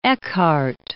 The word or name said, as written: Eckart